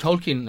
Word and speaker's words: Tolkien: Yes, it's pronounced Tolkien, apparently, point of contention in the movie.